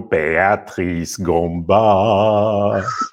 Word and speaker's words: Beatrice Gamba: Welcome to the show, Beatrice Gamba.